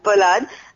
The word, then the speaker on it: Pollard: Yeah, Pollard, and he's a Mumbai Indian player, and he was insured for 15 crores from Oriental Insurance.